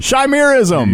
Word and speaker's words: chimerism: With different pigmentation, and after investigating, she discovers she's only one of about 100 people to ever have, I guess it's pronounced chimerism? – Chimerism.